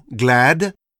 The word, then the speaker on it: glad: Ainsi dans glad, sad, on entend le D comme dans le français salade.